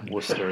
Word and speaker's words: Worcester: Is it pronounced Worcester? – Yeah, Worcester is how you pronounce it.